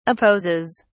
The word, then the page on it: opposes: British English